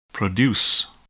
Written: produce

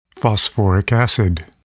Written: phosphoric acid

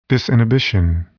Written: disinhibition

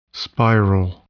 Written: spiral

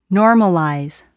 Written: normalize

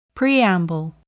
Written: preamble

